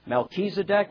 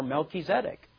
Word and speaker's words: Melchizedek: It can be pronounced Melchizedek or Melchizedek.